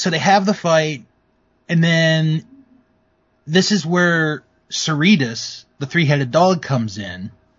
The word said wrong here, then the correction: Cerberus: Did I say that right? – No, I believe it's the name Cerberus. – Cerberus, okay.